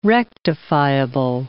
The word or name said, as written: rectifiable